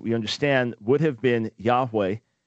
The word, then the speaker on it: Yahweh: In other words, if we put it in English, as we would most rightly pronounce it, Yahweh or Yahweh. What's the difference? There is no difference. Just to explain this, the Hebrew consonant W, when it would come into English, would come into English as a V.